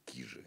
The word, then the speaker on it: Кижи: Она правильно говорит кижи, а не кижи.